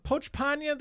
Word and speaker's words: Pochepnya: Pochpanya. – Ah, he's Chinese. – No, Pochpanya, that sounds very Russian. – Oh. – Yeah. P-O-C-H-E-P-N-Y-A.